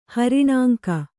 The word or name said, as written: ಹರಿಣಾಂಕ